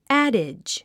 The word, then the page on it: adage: ǽdidʒ; アデッジ